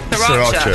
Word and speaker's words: Sriracha: Sriracha.